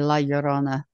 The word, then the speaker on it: La Llorona: And yes, just for the record, it took me about 5 minutes to teach him how to say La Llorona properly.